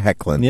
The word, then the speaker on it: Hoechlin: Is that how you say it? – That's how you say it. – Tyler Hoechlin. – Yeah. – That's like way easier to pronounce than Tyler Hoechlin. – Yep, it is. – Yeah. – It definitely Hoechlin. – Is. Like quit heckling in the comedy club.